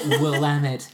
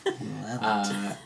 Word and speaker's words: Willamette: Willamette Valley. Willamette.